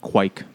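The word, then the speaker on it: Kveik: It's pronounced quike, and I just found out... – Well, they should change that letter then. – Yeah, I mean, it's just a... – Or add a few more.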